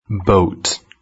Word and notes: boat: British English

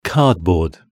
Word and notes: cardboard: British English

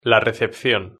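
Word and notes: la recepcion: Spanish (Spain)

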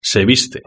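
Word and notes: se viste: European Spanish

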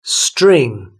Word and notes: string: British English

